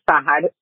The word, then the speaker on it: Sahad: My name is Sahad, phonetically pronounced S-A-H-A-D.